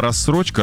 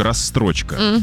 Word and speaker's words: рассрочка: Вместо слова «рассрочка» — «расстрочка». Я возьму это в расстрочку.